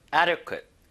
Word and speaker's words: adequate: 적정, 영어로 adequate. adequate. 발음이 좀 어려운 게 흠입니다. 너무 좋은 단어인데 적당한이란 뜻이거든요. adequate. adequate. 자, 앞에를 힘을 좀 주셔야 되는데 이 D가 살짝 리을 발음처럼 변하긴 변해요.